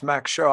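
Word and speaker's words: Mach Schau: It's funny that it's sort of pronounced Mac Show. I was thinking it was a Mach Show, like with the like the German, like the Beatles.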